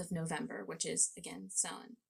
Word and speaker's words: Samhain: Yes, Samhain.